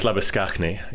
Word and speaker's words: Labuschagne: Yes, Manus Labuschagne is the South African pronunciation. He is South African by origin.